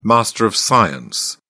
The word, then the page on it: master of science: British English